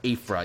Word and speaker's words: Aphra: Aphra. – Aphra, is that how it's pronounced? Aphra? – Yep. – Aphra, yes.